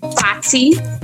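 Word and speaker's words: Fati: I released it in December, well, sorry, October, but my name is pronounced Fatih, capital F A T, lowercase I, because I'm short, sweet, and petite, and a little foxy.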